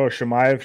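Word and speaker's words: Chimaev: How do you say his last name? – Oh, Makaev. – What? – Oh, Shamayev. – Shamayev. Yeah.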